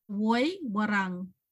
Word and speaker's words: Woi Wurrung: Woi Wurrung. So you have to roll your R's. Wurrung. – Woi Wurrung.